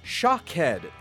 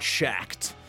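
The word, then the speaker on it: Shaked: The name of the guy who ripped off Wordle is pronounced Shockhead, not Shacked. Source, it's a Jewish name, and I'm Jewish. In Hebrew, Shockhead means almond. You can also use Google Translate to hear how that sounds.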